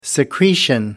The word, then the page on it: secretion: American English